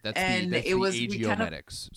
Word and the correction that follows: AGEO: IGO Medics. – Makes sense. I'm pronouncing it like it's the friggin' Art Gallery of Ontario down the street, you know? AGEO. It's spelled AGEO. So AGEO is much better.